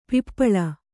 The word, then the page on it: ಪಪ್ಪಳಿ: kannada